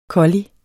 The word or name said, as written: collier